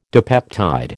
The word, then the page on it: dipeptide: American English